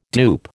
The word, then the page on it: dupe: American English